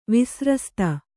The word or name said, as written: ವಿಸ್ರಸ್ತ